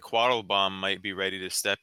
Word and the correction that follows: Quattlebaum: You mispronounced his name, by the way. – Oh, sorry, Quattlebaum. Sorry, Quattlebaum. It's my accent.